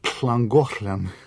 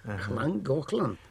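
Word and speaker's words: Llangollen: Как это надо долго учиться его произносить, это же произносится особенно Хлангохлен. – О, вы в Хлангохлене? – Да. – Хлангохлен, да. А начинается с двух «л». – Да. – Но тем не менее надо произносить «х» вначале. Да. Хлангохлен.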